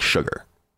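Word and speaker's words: Chigurh: And as long as Sugar never got, and I can never really say his name because they do say in the book that it's pronounced like Sugar.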